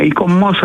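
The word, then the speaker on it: ICOMOS: Αν δεν το γνωρίζετε, αυτό ακριβώς κάνει το ΙΚΟΜΟΣ ή το, δεν ξέρω αν πρέπει να το πω, ΑΙΚΟΜΟΣ. Πρώτα απ' όλα, καλησπέρα σας. – Καλησπέρα σας. Ναι, ΙΚΟΜΟΣ θα το λέγαμε, γιατί έτσι προφέρουν όλο με τον γαλλικό τρόπο.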